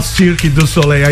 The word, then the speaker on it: Cirque du Soleil: Por favor, qual que é a pronúncia correta em francês, você que esteve lá durante muito tempo? Fala pertinho do microfone. – Cirque du Soleil. – Eu falei certo.